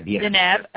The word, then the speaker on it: Deneb: Three of the Pleiadian star systems have human life as we know it, with the most advanced system being Deneb. Is that how you say it? D-A-N-E-B. – Deneb. I want to pronounce it, yes. – Okay, of Tegeta. Another system is Taro, T-A-R-O, which circles Alcyon, which is the great central sun.